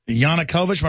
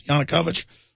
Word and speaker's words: Yanukovych: How do you pronounce that? Yanukovych? Am I pronouncing that right, guys? Yanukovych? I am pronouncing it right.